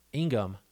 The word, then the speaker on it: Ingham: Big shout out to our buddy Sean, and also big shout out to Dr. Elaine Ingram. Ingham, there's no R in there. I've been saying it wrong the whole time. I just read it. Elaine Ingham.